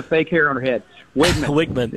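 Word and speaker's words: Weigman: Okay, the E throws things off because it's spelled W-E-I. – Yeah. – It is Wigman, W-I-G.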